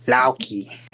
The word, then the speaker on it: Loki: So there's a little bit, and Loki would be more like Lauki, and Thor would be Thor.